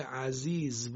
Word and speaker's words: عزیز: کلمه عزیز زش زی فارسیه، یعنی همون جوری که ما ز رو در فارسی تلفظ میکنیم، اینجا هم کلمه عزیز زش باید همون جوری تلفظ بشه.